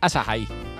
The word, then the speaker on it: Asahi: He's not pronouncing acai wrong. It's A-S-A-H-I.